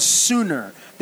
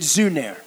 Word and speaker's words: Sunner: It's called Sooner. They pronounce it Zuner. S-U-N-N-E-R, and the S has a little I know exactly the beer that you're talking about, because I've been there.